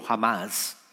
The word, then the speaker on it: Hamas: Porque lo que ocurrió con Hamas, el ataque bélico que hizo Hamas, que se pronuncia teóricamente en español, entiendo jamás, fue algo que la semana pasada tomó por sorpresa al pueblo de, de Israel.